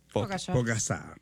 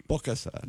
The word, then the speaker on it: Pogačar: E vediamo se riuscirà anche a farla il nostro dillo tu, Pogačar. – Pogačar. – Pogačar.